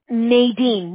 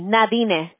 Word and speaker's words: Nadine: Okay, primero lo que yo le estoy mandando son dos correos electrónicos que viene de Nadine Medina. Eso soy, ese es mi nombre, okay? – ¿Noemí? – Nadine, Nadine. No hay modo como pronunciarlo en español.